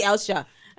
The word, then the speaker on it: Ilsha: Because they pronounce my name ILSHA. – I do too, until you said it just now. – But my parents call me ELSHA.